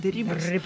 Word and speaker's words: Дерибас: Генерал-майор русской армии Дон Хосе Дерибас, вот ударение надо правильно ставить, Дерибас, взял турецкую крепость Хаджибей, которая была будущей Одессой.